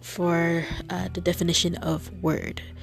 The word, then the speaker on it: wyrd: Well, okay, discord, ruin, and rebirth was what they were going for, and the word that they encompassed around was literally word, W-Y-R-D, and was different from this word compared to the regular word that we hear, which is spelled W O R D, word that is spelled W Y R D.